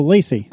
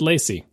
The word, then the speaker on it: LaCie: L-A-C-I-E. It's been around for ages. – Oh, Lacey. – I remember getting hard drives from them for my classic Mac. I always said Lacey. – I always say Lacey in my head, but I don't know.